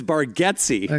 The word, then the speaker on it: Bargatze: And Nate's like, yeah, but you've always said my name wrong, buddy. So it's Bargatze.